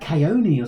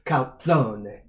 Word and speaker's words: calzone: I thought it was like caione or something. – No. It's pronounced calzone.